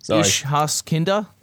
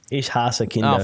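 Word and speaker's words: Ich hasse Kinder: Ish-has-kinder? Have I said that right? – No, you haven't. Ish-has-kinder.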